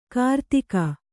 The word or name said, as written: ಕಿರುದೇಗು